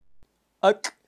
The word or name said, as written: ak